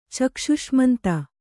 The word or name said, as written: ಚಕ್ಷುಷ್ಮಂತ